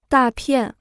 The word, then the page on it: 大片: dà piàn